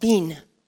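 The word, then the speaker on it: bin (בין): The Hebrew word for understand is pronounced bin.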